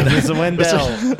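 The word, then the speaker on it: Mr. Wendal: And Mr. Wondell by the Arrested Development, all on the top 100. – Love your pronunciation of Mr. Wendell, by the way. – Mr. Wendell.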